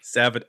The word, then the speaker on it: Savatage: Is that how you pronounce that? – Savatage. – Savatage. Okay. Sorry. It looks like Savatage.